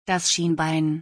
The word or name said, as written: das Schienbein